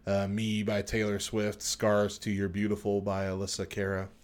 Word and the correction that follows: Alessia Cara: Alessia Cara.